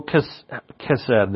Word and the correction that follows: Chesed: I pronounced this word wrong in the beginning, and now it's stuck in a neuron in my brain. And it had been pointed out to me how to pronounce it, so as you can see, that's why I'm struggling with the pronouncement. Chesed.